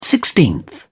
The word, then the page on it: sixteenth: British English